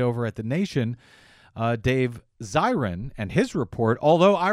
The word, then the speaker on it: Zirin: That came to us from a listener, Fred G, who says, it's Zirin, long I.